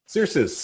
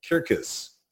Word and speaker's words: Cercis: Sirius, C-E-R-C-I-S. You can pronounce it however you like because the Romans would never pronounce the word C-E-R-C-I-S, Sirius. I took Latin. I only took 2 years of it, but one thing I remember from it is that they didn't have a soft C. They didn't say Julius Caesar. They said Julius Kaiser. And so we would be saying, if we were Romans, referring to redbud trees, Circus.